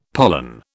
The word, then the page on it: pollan: British English